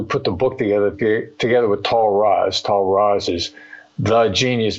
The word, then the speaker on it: Tal Raz: Tal Raz. – T-A-H-L. Tal, like, rhymes with Paul, but it's an Israeli name. R-A-Z.